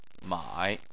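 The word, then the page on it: mai: tone 3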